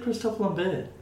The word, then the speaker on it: Christophe Lambert: Glad you said Christophe Lambert. Good for you. – What did didn't go Christopher Lambert.